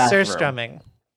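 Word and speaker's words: surströmming: It's pronounced surstrumming.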